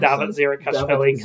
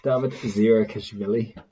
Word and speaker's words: Davit Zirakashvili: It's Rabah Slimani, who starts, and Davit Zirakashvili coming on off the bench.